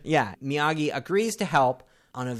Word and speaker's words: Miyagi: And he's like, Miyagi.